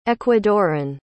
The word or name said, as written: ecuadoran